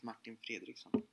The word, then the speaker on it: Martin Fredriksson: The real way, I would say, in Swedish or in English? – Either way. – Well, in Swedish, I would pronounce it Martin Fredriksson. – Fredriksson. – Yeah. It's good. – And in English, you would say Martin Fredriksson. – Yeah, exactly. Yeah.